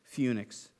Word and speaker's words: Phoenix: Phoenix, a harbor of Crete. That's actually how you pronounce it. I looked it up.